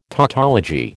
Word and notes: tautology: American English

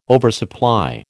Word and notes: oversupply: American English

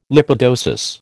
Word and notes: lipidosis: American English

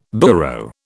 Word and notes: burro: British English

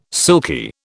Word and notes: silky: British English

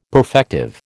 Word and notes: perfective: American English

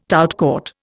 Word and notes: tout court: Indian

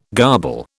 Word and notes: garble: British English

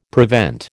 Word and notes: prevent: American English

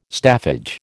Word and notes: staffage: American English

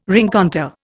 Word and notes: rencontre: Indian

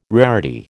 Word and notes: rarity: American English

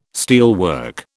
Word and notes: steelwork: British English

